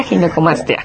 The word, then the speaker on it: ginecomastia: Para empezar, la palabra, le decía, ¿cómo se pronuncia? Ginecomastia, ginecomastia. – Sí, exacto. Ginecomastia.